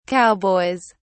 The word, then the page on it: cowboys: British English